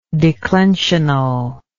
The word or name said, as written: declensionally